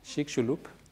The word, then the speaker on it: Chicxulub: Le village, c'est presque impossible de prononcer, même pour moi, Chicxulub, voilà, je l'ai dit.